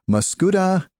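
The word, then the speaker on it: Mascoutah: Let's go to Nicole I'm going to try to say your name, the name of your town, Nicole, Muscoota, Illinois. Did I get that right? – Yes, sir. You got it right.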